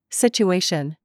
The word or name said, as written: CHURCH